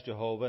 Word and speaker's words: Jehovah: So sometimes you'll hear it as Jehovah and sometimes as Yahweh.